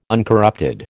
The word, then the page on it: uncorrupted: American English